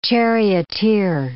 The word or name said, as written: charioteer